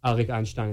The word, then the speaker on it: Arik Einstein: And like my namesake is also from a song by an artist named Arik Einstein, is how you say it.